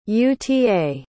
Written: uta

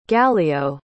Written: gallio

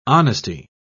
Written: honesty

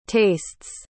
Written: tastes

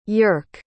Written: yerk

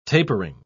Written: tapering

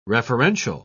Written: referential

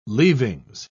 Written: leaving

